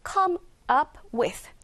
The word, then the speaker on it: come up with: 여기서 발음 빨리 하다 보면요. come up with. 자, 이 put 사운드가 뒤로 넘어가게 돼서 come up with, come up with, come up with로 들릴 겁니다.